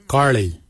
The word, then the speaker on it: giilyi: Dab is spelled D-H-A-A-B. It is a traditional knife, a dab. For boomerang, we say garli. Garli is the traditional name for boomerang. Garli is spelled G-I-I-L-Y-I, garli. For the traditional stone axe, we say gotch, gotch. Gotch is spelled G-O-O-D-J, gotch.